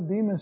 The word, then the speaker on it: Bema: Sometimes they call this, because of the Greek word, they call it the Bema seat. B-E-M-A. So if you see that word, it's the English pronunciation of the Greek word Bema, which speaks of that place where rewards are handed out.